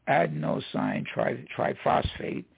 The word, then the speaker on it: adenosine triphosphate: ATP, which is adenosine triphosphate, is the correct pronunciation, if you will, is the principal molecule for storing energy in the cells.